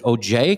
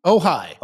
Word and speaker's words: Ojai: There is unrest in the scenic little town of, and I don't know how to pronounce this. Is it OJ, California? – Ojai.